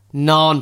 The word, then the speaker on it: naan: Right, William, we need to settle up on this nan and non, because loads of people have been getting in touch with me on Twitter and on Insta saying, oh, you said it right, or William said it wrong, or you said it wrong. It's non bread.